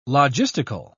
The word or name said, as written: logistical